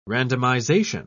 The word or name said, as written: randomization